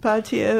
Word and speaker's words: Peltier: Bir de Leonard Peltier, nasıl okunuyor bilmiyorum. – Peltier, evet.